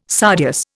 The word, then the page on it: sardius: Indian